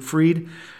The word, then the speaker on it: Fried: In the National League, I'm looking at Max Fried, spelled Fried, but he pronounces it Fried, of the Atlanta Braves.